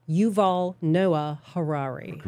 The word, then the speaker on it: Yuval Noah Harari: Yuval Noah Harari.